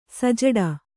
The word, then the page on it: ಸಜ್ಜೋಡು: sajjōḍu